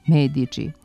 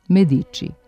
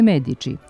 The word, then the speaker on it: Medici: U toj seriji, istina na engleskom, dosledno se izgovara onako kako treba, Medici. Ali u spikerskoj najavi na našoj televiziji čuje se onako kako se kod nas uvrežilo, Medici. Na italijanskom je Medici,